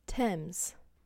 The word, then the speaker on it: Thames: Thames.